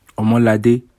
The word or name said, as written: Omolade